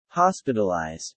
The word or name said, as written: hospitalized